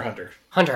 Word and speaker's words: Hunter x Hunter: Hunter Hunter Hunter. Sorry, you don't pronounce the X.